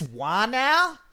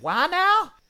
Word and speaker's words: whānau: Am I saying that right? Whānau? Whānau. – Oh, I miss tourists. I want them to come back. – Was that pretty spot on, do you reckon, That was brilliant.